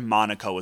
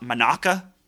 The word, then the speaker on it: Monaco: She says the word Monaco is what happens, but she pronounces it Monaca.